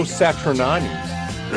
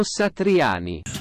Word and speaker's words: Satriani: He's going to be playing with Joe Satriani. Did I say that right? – No. – Joe Satriani.